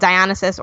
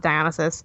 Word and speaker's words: Dionysus: There's a GameOnesis, it's like a blend of gaming and Dionysus, or Dionysus, depending on how you want to pronounce that. I pronounce it Dionysus.